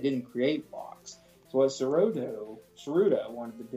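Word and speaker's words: Tsuruta: I guess it's Sarutu. It's T-S-U-R-U-T-A. I'm going to say that initial T is silent.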